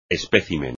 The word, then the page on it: espécimen: espagnol